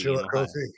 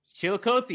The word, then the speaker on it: Chillicothe: Chillicothe. – Chillicothe, you got it. Yeah, Chillicothe, Ohio.